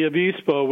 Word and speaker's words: avispa: Yeah, the avispa, which translates into wasp in Spanish.